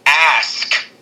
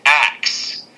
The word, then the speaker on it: ask: It's like when King Ricky says the word ask and pronounces it axe. A-S-K. Ask.